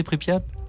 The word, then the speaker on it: Pripyat: Comment vous prononcez Pripyat ? – Pripyat. – Ah oui, c'est Pripyat. Pas mal.